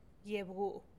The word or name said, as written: giebguu